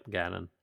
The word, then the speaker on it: Ganon: Yep, Ganon.